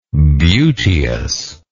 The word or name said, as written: beauteousness